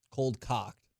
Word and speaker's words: cold cocked: So, did I say it wrong or something? – Is it cold clocked? – I thought it was cold cocked. – Cold cocked. Okay.